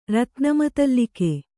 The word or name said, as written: ರತ್ನಮತಲ್ಲಿಕೆ